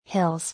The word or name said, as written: Hills